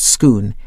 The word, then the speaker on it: Scone: Scone, which is spelt like the baked afternoon tea delicacy, but pronounced Scone,